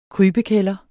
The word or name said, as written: kryb